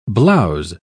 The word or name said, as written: blouse